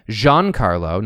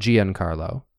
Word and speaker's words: Giancarlo Esposito: so I learned firsthand that his name is in fact pronounced Giancarlo, not Giancarlo, Esposito. – Esposito. – Esposito, and he's very particular about the pronunciation. – Oh, okay. – But everybody says it wrong. – Giancarlo makes sense. – Giancarlo, it's three syllables instead of four, and it's just the inflection is different in the last name.